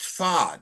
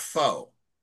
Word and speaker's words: pho: And I found out the other day that it's pronounced pho, not pho.